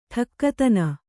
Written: ಟಕ್ಕುತನ

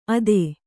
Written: ಅತ್ತೆ

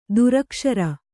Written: ದುರಕ್ಷರ

